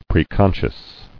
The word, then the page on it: Preconscious: pre·con·scious